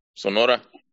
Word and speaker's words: Sonora: I can't remember how to pronounce Sonora. – In Sonora. That's exactly right.